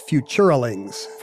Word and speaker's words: Futurelings: I think it's 4 syllables. It's Futurelings. – For Churlings. – Right, like chitlings.